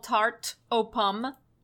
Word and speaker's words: Tarte aux Pommes: T-A-R-T-E, Tarte. Mm-hmm. Space, A-U-X, O, space, pomme, P-O-M-M-E-S. The French have a lot of letters at the ends of words that are not pronounced.